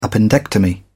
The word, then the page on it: appendectomy: British English